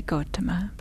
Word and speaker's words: Gotama: Some people pronounce it Gotama. In Pali, we would say Gotama.